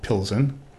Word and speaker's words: Pilsen: which is the German pronunciation and the Czech pronunciation, as with so many other Czech words, is very difficult. we'll do our best, but since we have this nice German pronunciation, we'll call it Pilsen for now.